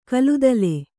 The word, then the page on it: ಕಲುದಲೆ: kaludale